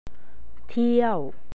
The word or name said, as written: teaw